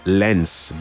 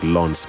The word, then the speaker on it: Lens: It's PSG currently playing out a 0-0 draw in their French Ligue 1 fixture against the team that you love to call Lens, based on the spelling, but it's pronounced more like Lens because it's a French side.